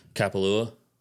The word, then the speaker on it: Kapalua: We'll see what he does with, I mean, he has, what, 4 weeks till Kapalua. I said it right this time. We'll see what shows up there. I mean, something about Scottie Scheffler, he loves grainy Bermuda greens. – Will Bryson be at Kapalua? – No.